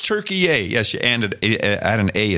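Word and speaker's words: Türkiye: We should say Turkey-ay. It's the new way to pronounce Turkey. It's Turkey-ay.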